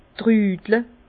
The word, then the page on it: trüdle: Bas-Rhin; Herrlisheim